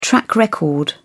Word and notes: track record: British English

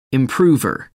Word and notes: improver: American English

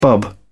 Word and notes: bub: British English

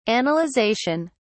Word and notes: analyzation: British English

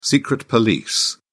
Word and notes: secret police: British English

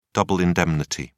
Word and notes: double indemnity: British English

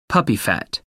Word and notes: puppy fat: American English

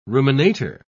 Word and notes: ruminator: British English

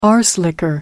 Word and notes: arse licker: American English

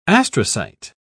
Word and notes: astrocyte: British English

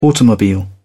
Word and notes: automobile: British English

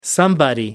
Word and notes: somebody: American English

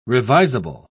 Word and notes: revisable: British English

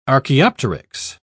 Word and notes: archaeopteryx: British English

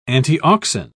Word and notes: antiauxin: British English

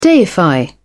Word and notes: deify: British English

